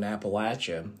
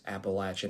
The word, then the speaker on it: Appalachia: I grew up in a small town in Appalachia, and that's Appalachia, not Appalachia to you folks out there. Um, please pronounce it right.